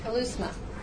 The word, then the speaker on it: keleusma: It's spelled K-E-L-E-U-S-M-A, kalousma.